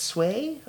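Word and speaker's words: Seu: It's S-E-U. So it's not Sue, it's Sway, I think.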